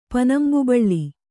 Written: ಪನಂಬುಬಳ್ಳಿ

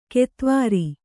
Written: ಕೆತ್ವಾರಿ

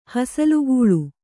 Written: ಹಸಲುಗೂಳು

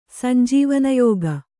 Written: ಸಂಜೀವನಯೋಗ

